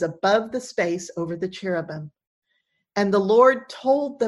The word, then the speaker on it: cherubim: We say cherubim in English is how we pronounce it, and it's the plural of cherub.